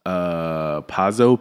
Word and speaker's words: Pazzo: Pazzo, P-A-Z-Z-O.